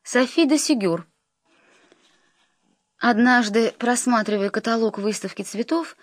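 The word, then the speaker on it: Ségur: Сигюр — типичная французская фамилия с ударением на последнем слоге и частичкой «д», свидетельствующей об аристократическом происхождении.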